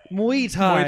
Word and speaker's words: Muay Thai: I'm into Muay Thai. I'm really good at it. – How do you pronounce that? I didn't bother looking it up. Muay Thai.